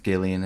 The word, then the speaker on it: Gillian: Uh, Is it pronounced Gillian? Jenny Slate. It might be Jillian. – Actually, I was going to say. I hope yeah. it's Jillian. I've been saying Jillian for 25 years.